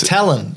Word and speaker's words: ptyalin: So how do you pronounce P-T-Y-A-L-I-N? – P-T-Y. So like pterodactyl, yeah, that's so it. Tear. – Talanin. – Talanin. – So this... – Talon. It's just talon. – Just talon. – Yeah. – Okay. – P-T-Y-A-L-I-N.